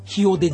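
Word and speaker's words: Rio: あの言葉の一番前に来る R は、うんうん、ハ行の発音になります。 – ヒオデジャネイロとか。 – そうですね。リオじゃなくてヒ、リオじゃなくてヒって言ってますよね。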